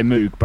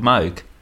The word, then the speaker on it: Moog: And the company's called Moog. It's just us that say Moog because we can pronounce double O's.